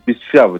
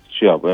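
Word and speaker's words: Bitshiabu: Bu arada doğru telaffuz ettim değil mi? El Shaddai Bichabu. – Bichabu tabii, yani tabii transfer olduktan sonra kendisine de sorarız telaffuzunu.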